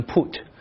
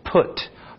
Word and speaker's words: put: 首先要注意它的 pronunciation 发音，不要读成 put。就像我们前面说过一些词，good 不要读成 good，对吧？它是像那个书不是 book，它是 book，它是那个呃那个短音，所以这个也是 put。不要读成 put。